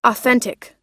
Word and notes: authentic: American English

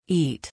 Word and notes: eat: long-i

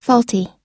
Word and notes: faulty: American English

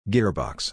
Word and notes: gearbox: ir

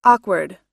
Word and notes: awkward: American English

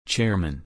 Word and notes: chairman: er

